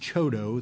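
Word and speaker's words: CHDO: And then the second amendment concerns a so-called Community Housing Development Organization set-aside funding, pronounced CHODO, the acronym.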